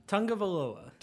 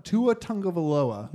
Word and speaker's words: Tagovailoa: how do you pronounce his last name? – Tagovailoa. – Tua Tagovailoa. – Nice, you nailed it.